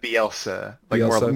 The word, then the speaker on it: Bielsa: As far as I know about Bielsa, that's how you say his name, correct? Is it Bielsa? – Bielsa. More emphasis on the L.